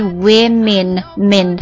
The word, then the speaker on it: women: 注意一下它的读音呢，第一个 woman 呢然后它变成复数之后它是 women，它是 in 啊 women。